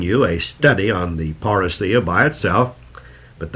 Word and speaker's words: parousia: The Bible calls this the parousia, P-A-R-O-U-S-I-A, a Greek word that we bring right over into the English, often pronounced parousia, but it's a Greek word. It should be pronounced parousia.